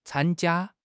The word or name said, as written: can1 jia1